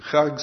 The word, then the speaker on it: Chag: And Chag, C-H-A-G, they have a chet in there. You got to pronounce it just right if you're going to be authentic.